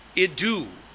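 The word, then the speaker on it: idu (ἰδού): It's pronounced idu, with the accent on the second syllable.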